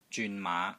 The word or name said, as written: ZYUN3 MAA5